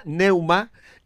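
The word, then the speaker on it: pneuma: Las palabras bíblicas para espíritu son ruach, R-U-A-C-H, en hebreo, ruach, y neuma, con la P delante, que no se pronuncia, neuma, en griego son términos onomatopéyicos ¿eh?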